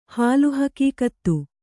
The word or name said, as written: ಹಾಲುಹಕೀಕತ್ತು